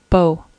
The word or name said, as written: beau